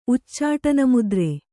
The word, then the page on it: ಉಚ್ಚಾಟನಮುದ್ರೆ: Kannada